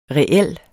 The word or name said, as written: reel